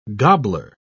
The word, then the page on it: gobbler: British English